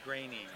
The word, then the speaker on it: Groening: Groening. – Groening. I just want to make sure I got his name right. – He always says his name rhymes with complaining.